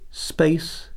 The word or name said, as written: space